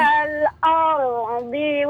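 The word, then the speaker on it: wal ardi (الأرض): Al bacanya aldi, di bukan terdengar huruf dal. Kadang do sama dal itu cara bacanya hampir sama kayak wal ardi. – Iya yeah, betul. – Ha-ha. Betul. Ha. Do di sini nih wal aldi, di, di. Nah, bukan wal aldi.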